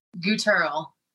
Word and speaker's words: Guterl: And I think it was your dad who joined in on the YouTube chat and he said, it rhymes with Pearl. And I was like, okay, I think we got it right. So can you say your last name the correct way? – Guterl.